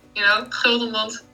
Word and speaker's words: Gudmund: Yeah, and it's it's a Dutch name, so usually you would say, Gudmund, but okay, you pronounced it the right way in English, I guess so.